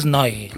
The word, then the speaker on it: Neu: el Neu. Grup Neu, però jo no sé exactament com es pronuncia. – Tinc entès que en Alemanya es diu, és Noi.